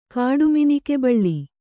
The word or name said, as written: ಕಾಡುಮಿನಿಕೆಬಳ್ಳಿ